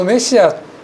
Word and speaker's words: メシア: それは、この幼子は私たちの、全世界の人々のメシアだ。このメシアという言葉ですが、これは救い主という意味の旧約聖書が書かれた、まあ、ヘブライ語というんですがの、発音です。そしてこのメシアというのを、まあ、英語読みにしますと、皆さんよくご存知のメサイアになります。